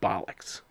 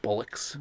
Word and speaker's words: bollocks: The Sex Pistols, Never Mind the Bollocks, was banned just because it said the word bollocks. – Bollocks is how they pronounce it. – Bullocks. – Bullocks. – Bollocks. – Yeah, bollocks or bullocks, I believe.